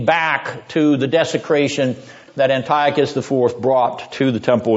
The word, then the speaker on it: Antiochus: It's pronounced, Antiochus is alright, but Antiochus, is pronounced Antiochus.